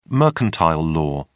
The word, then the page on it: mercantile law: British English